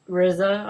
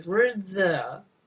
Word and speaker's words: RZA: MC Gainey, Don Johnson, Joseph Gordon-Levitt, Kerry Washington, Anthony LaPaglia, Raza, Tom Wopet. – RZA, right? Pronounce things differently.